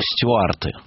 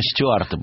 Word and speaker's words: Стюарты: Наталья Ивановна, вы знаете, я вот в школе почему-то, будучи маленьким глупым очкариком, называл их стюарты. Я всегда ставил ударение стюарты почему-то.